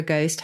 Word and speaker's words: ghost: for a ghost, had an H in it. And so they lobbed an H into our words, and that's how we've got the silent H in our ghost, ghastly, and a ghast, because there was a kind of ricochet effect.